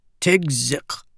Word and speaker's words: Tigzik: That kuk sound on the end of his name sounded like choking when someone pronounced it correctly. She just called him Tig instead. – Tigzik, Hukin said.